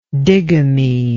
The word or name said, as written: digamist